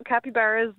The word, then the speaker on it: capybaras: This year they're capybaras. Am I pronouncing that animal's name right? – Yep, capybaras is the pronunciation.